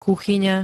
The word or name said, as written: kuchyňa